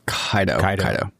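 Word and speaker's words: Kaito: Or is it pronounced Kaido or Kaido? – Kaido. – All right, yeah. Kaido.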